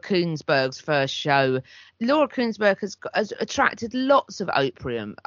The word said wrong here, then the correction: opprobrium: How's it pronounced? Opprobrium? Lots of opposition. – Oh, opprobrium. Yeah.